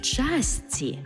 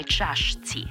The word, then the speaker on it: чашці: Мало чаю чи кави в часці, а не чашці.